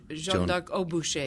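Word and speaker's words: Jeanne d'Arc au bûcher: And so now this piece that's coming up on the 17th and 18th, Jeanne d'Arc de Boucher, did I say that correctly? – Jeanne d'Arc au Boucher, yes.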